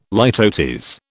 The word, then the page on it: litotes: British English